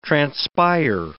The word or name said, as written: transpire